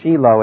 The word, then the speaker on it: Shiloh: In Jeremiah, actually there's more than one possible reference to this particular destruction of Shiloh, or Shiloh as it should be pronounced.